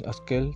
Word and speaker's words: asquel: Con los asqueles, los asqueles si no los conocen son unos pequeños animalitos como si fuera una hormiga pequeñitita De hecho viene del náhuatl asquel, pero como no se pronuncia tl, es asquel, así tal cual, y significa hormiga pequeña.